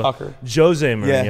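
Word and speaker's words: José Mourinho: So Jose Mourinho. Yeah. That's the Portuguese way of pronouncing it.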